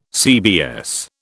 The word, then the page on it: CBS: British English